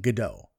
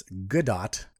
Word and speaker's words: Gadot: I always thought it was Gal Gadot, that the T was silent. But after reading that, who did the article? UK Business Insider interview with her. She talked about how to pronounce her name because apparently everyone was thinking Gadot. No, it's Gadot.